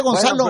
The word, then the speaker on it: Gonçalo: Ah, sí se dice Gonçalo. Bueno, pues, el compa Gonçalo. – Sí, porque es una C y luego como una colita. – Sí, como una, ándale. Ajá. Gonçalo Ramos.